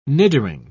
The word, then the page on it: niddering: British English